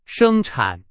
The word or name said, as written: 生产